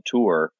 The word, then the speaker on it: Tawe: And so there's a nice app called Tour. It's not spelled like it sounds. It's spelled T-A-W-E.